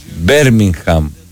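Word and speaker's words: Birmingham: Οι πόλεις προφέρονται όπως λέει το στοίχημα. Πίτερμπρο, Πιτερμπόρο, Μίτλεσμπρο. Ό,τι γράφει εδώ πέρα το δελτίο. Μπέρμιγχαμ, τέλος.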